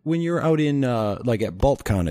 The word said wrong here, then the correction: Balticon: it's called, right? – Balticon, yeah. – Balticon, there we go. I knew I was saying it wrong.